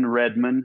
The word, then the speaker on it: Redmon: My Instagram handle is my name, Nathan Redmon, health coach. And my last name is spelled R-E-D-M-O-N.